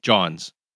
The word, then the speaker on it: jawns: John's. And it's a winter lager. And John is a Philly slang. It's spelled J-A-W-N-S.